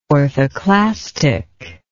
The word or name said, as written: orthoclastic